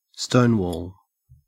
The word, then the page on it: stonewall: Australian English